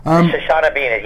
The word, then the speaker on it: Shoshana Bean: Featuring the voice, I think, of somebody called Shoshana Bean, if I've pronounced that right. – Shoshana Bean, yeah, she's the singer.